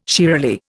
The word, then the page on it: shiralee: Indian